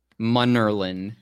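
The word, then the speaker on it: Munnerlyn: Mutterlin, yes. – Mutterlin. Captain Mutterlin. M-U-N-E-R-L-E-I-N.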